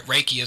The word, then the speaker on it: Reiki: People, first, if they can even pronounce it, but Reiki is the correct pronunciation.